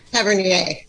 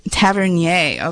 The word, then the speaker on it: Tavernier: How do you say his last name? Jules Tavernier? Is that right? – Tavernier. – Tavernier. Okay. French.